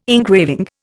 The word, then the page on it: engraving: Indian